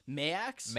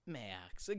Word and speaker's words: Maax: Mayax? – Mayax. Or Mayax. – Yeah, Mayax. Because I spelled it M-E-Y-A-X. – It's spelled M-A-A-X. – Max. Got it.